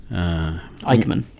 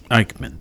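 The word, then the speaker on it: Eijkman: Christian Eichmann, E-I-J-K-M-A-N. I've seen this in writing, but I truly can't remember how to pronounce it. Anyway, Christian Eichmann. – Eichmann, thank you.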